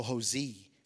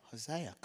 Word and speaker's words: Hosea: Hosea or Hosi, however you want to say it. Hosea. How do you say it? Hosea, cool.